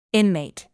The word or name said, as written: inmate